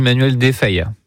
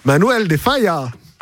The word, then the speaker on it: Manuel de Falla: Je me permets, on dit Manuel de Falla. – De Falla. – Faut le prononcer à l'espagnol, s'il vous plaît. – Manuel de Falla.